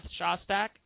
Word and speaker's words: Shostak: It is Seth Shostak.